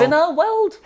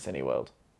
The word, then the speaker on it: Cineworld: considers it to be Cineworld. And actually, I will say, in their defence, not the only one. Some other people pronounce it Cineworld.